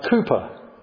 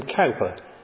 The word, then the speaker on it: Cowper: We only have to think of William Cooper. Some people will pronounce his name Cowper, C-O-W-P-E-R.